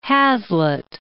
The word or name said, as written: Hazlitt